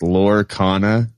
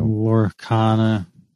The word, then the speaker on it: Lorcana: I guess it's pronounced Lorcana, like Arcana and Lore mixed together. – You know? Lorcana.